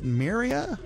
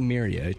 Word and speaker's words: Myria: I think it's pronounced, is it Myria? – It looks like Myria to me, yeah.